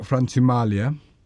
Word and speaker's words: Frantumaglia: But perhaps tell us a bit about Frantumaglia. – Very, very nice. Thank you. Silent G. – Silent G. Frantumaglia.